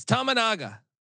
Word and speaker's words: Tominaga: And this all stems, we're talking about Tominaga, or I can't pronounce his damn name. I butchered every fucking dime. He's awesome at Nebraska, but go Gophers tomorrow. They're going to keep this close. – Yes, Tominaga.